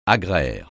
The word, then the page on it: agraire: French